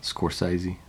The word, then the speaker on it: Scorsese: Did I say his name right? Were you about to correct me? – What? – Scorsese. – No, you did it, man.